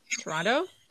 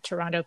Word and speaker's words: Toronto: And I know you're not supposed to pronounce the second T, but Do we say the second T? – Toronto? I think I do.